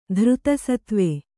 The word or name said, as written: ಧೃತಸತ್ವೆ